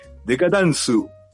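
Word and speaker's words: Dekatansu (デカダンス): para pasar el rato nomás, pero no, a recomendarle nomás Pasamos con la siguiente, Decadence, o su pronunciación en japo, Dekatansu.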